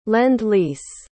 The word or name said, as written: lend-lease